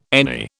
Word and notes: NE: British English

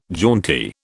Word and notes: jaunty: British English

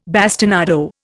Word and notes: bastinado: Indian

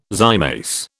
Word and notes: zymase: British English

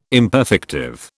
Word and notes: imperfective: British English

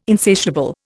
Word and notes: insatiable: Indian

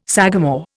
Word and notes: sagamore: Indian

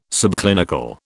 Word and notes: subclinical: British English

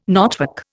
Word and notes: knotwork: Indian

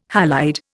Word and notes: halide: Indian

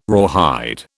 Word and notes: rawhide: British English

